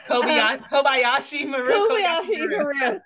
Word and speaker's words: Kobayashi Maru: I guess I was just thinking about the, and I'm going to probably pronounce this incorrect, the Kishimashi Maru. – Kobayashi Maru.